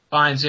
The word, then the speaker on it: Fiennes: Fiennes, yeah.